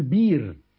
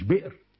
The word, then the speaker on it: بئر: ابو جعفر المدني، سواء فاء كلمه، عين كلمه، لام كلمه، مش هينطقه، فيقول ايه مؤمنون، ويقول بير مش بئر، ذيب مش ذئب، يقول كاس مش كاس، تمام؟